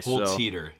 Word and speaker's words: Holzhueter: Is it Holtz Heater? You said you add the T? – You got it. You got it. – Okay. – Holtz Heater. You got it. – Mr. Yep. Holtz Heater, if you're listening back to this, I would have loved the chance to talk with you.